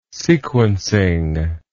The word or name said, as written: sequencing